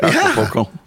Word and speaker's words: Acapulco: Acapulco, Mexico. Did I say that right? Acapulco? – Yeah.